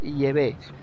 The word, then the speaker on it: Yahvé: Sí, yo creo que la verdadera pronunciación del Dios de Israel es Yahvé. No Yahvé, como dice la Iglesia Católica, sino Yahvé. I-E-V-E.